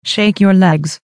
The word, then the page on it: shake your legs: American English